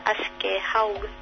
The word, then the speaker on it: Aschehoug: Se llama, la editorial tiene un nombre imposible en castellano, se pronuncia Aschehoug, se escribiría Aschehoug.